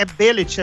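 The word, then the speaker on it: Bêlit: É, nos quadrinhos tinha a Bellet do, do, do Conan. É, Gordinho, não sei se, nunca sei se pronuncia Bellet ou, ou Bellet é billet, é fechado.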